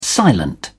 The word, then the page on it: silent: British English